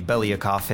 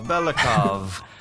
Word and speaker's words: Belyakov: I have Jimmy Belyakov in here with me, as always. – Hi, guys. My name is actually pronounced Belyakov, but good try. – Belyakov.